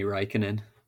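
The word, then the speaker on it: Räikkönen: I made me laugh internally, a quote from I'll butcher this name because I'm going to, it's Raikkonen. – Yeah, Kimi Raikkonen.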